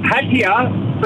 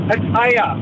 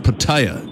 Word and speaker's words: Pattaya: The Aussies call it Pattaya, but it's actually pronounced Pattaya. – Right. So we've Aussified it to Pattaya, but it's pronounced Pattaya. – That's exactly right. That's how the Thais say it, Pattaya.